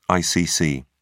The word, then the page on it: icc: British English